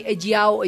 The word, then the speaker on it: ejiao: Ejiao, ejiao.